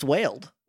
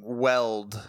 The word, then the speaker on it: Wild: Is that pronounced wild? – It's pronounced wailed. – Wailed, okay. – Which is how... – I was saying weld myself.